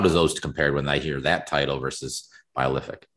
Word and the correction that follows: biophilic: I've never heard anyone say biolithic, so I don't know. But okay. – Biophilic. – Okay, fine. – I think that word. – Pronouncing this. It's biophilic.